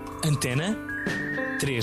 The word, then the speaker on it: Antena 3: And Antena 3. – Antena 3. Oh, Antena 3. – Okay. – um, yeah. Um, hi, it's Courtney Marie Andrews. I'm here with you in Portugal on Antena 3.